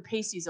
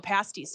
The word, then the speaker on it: pasties: The pasties always look so dang good. – Pasties, sorry, I said it wrong.